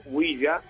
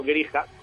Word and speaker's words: Ouija: Ouija, que no grija, como todavía alguien suele todavía decir. Incluso se escribe con la U, con diéresis. – Ouija, o U-I-J-A, viene del alemán y el francés, que dice sí, sí.